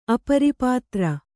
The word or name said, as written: ಅಪರಿಪಾತ್ರ